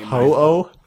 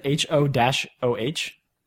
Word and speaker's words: Ho-Oh: Ho-Oh? – I think that's how you pronounce it. H-O-O-H.